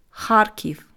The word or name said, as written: Kharkiv